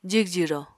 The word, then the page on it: jigjiro: Apatani